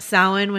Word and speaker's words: Samhain: She's talking about Samhain, which I used to pronounce Samhain for a really long time before someone actually said it to me, which is sort of a Gaelic Halloween.